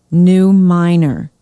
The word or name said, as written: new miner